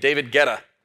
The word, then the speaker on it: Guetta: Also, all y'all Gen Zers with David, I heard learned how to pronounce his name, David Guetta, right? Did I say it right? I said Guetta earlier because that's what it looks like.